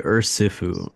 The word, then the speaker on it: Urshi: Urshi, like she.